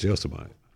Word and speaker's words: Yosemite: Why'd you say it wrong? It's Yosemite.